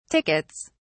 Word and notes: tickets: British English

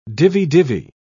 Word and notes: divi-divi: British English

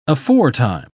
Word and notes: aforetime: British English

